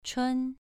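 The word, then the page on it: chun: tone 2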